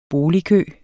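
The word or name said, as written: BOLIGKØ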